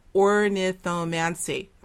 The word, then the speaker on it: ornithomancy: But let's get back to ornithomancy. Am I saying that right? Ornithomancy. Yeah, I think so.